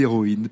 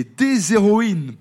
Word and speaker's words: héroïnes: Donc on ne fait pas la liaison, on dit le héros. Et en revanche, ce n'est pas le cas pour le H de héroïne, car on dit des héroïnes. Particularité, en effet, dans la prononciation entre ces deux mots, qui ont pourtant bien la même signification et qui ont la même origine. On dit un héros et des héroïnes.